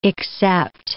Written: except

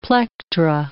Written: plectra

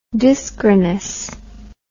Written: dyschronous

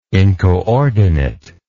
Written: incoordinate

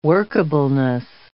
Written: workableness

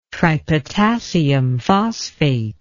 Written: tripotassium phosphate

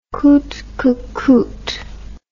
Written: coute que coute

